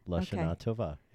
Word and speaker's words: Lashana Tova: How do you say that? – Lashana Tova. – Lashana Tova. – Mm-hmm. Lashana Tova. Yeah, you got it.